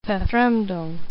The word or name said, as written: Verfremdung